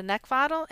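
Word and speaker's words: Neckvatal: It's Brenda Neckvottel, and the last name is spelled N-E-C-K, like the thing you want to choke, V as in Victor, A-T-A-L.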